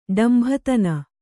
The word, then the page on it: ಡಂಭತನ: ḍambhatana; Kannada